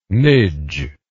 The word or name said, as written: nidge